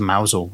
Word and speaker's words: Mousehole: which is the road connecting Penzance to Mousel, or was then. – Mousehole for any of I'm pronouncing it Mousel because it's written Mousehole.